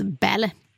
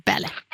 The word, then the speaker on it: Balle: Ja, och Solveig heter, det stavas B-A-L-L-E, men uttalas Bälle. Bälle. Solveig Bälle är det som har skrivit om uträkning av omfång som består av 7 böcker.